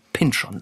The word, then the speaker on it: Pynchon: Aber wir wissen nun immerhin, dass er Thomas Pynchon sagt. – Das heißt, sozusagen in dieser Serie hat man ihn so ausgesprochen. Ja, es hat sich aber anscheinend, würde ich sagen, 20 Jahre ist es jetzt her, nicht groß rumgesprochen.